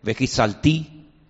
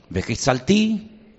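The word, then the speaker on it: vehitsalti: Sacaré, vejotsetí, libraré, vejizaltí, redimiré, vegaltí, yo tomaré velacachti. Las cuatro terminan en ti, ti, ti, ti. ¿Lo veis? ¿Podéis pronunciarlas conmigo? Bejotzeti, vejitzalti, vegalti, Velacachti.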